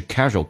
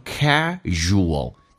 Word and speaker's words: casual: 这句话最难的一个点就是 casual clothes，因为这两个单词读的跟原来的这个发音基本上都不太一样。第一个 casual，正确的发音 casual，但是 ua 一般组合在一起经常会把 u 去掉，保留一个 a，就变成 casual。